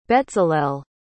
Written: Betzalel

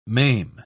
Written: maim

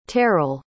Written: terral

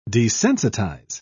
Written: desensitize

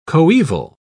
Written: coeval

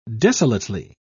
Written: desolately

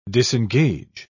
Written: disengage

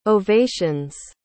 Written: ovations

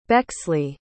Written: Bexley

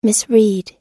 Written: misread

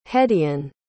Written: hedeon

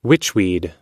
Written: witchweed

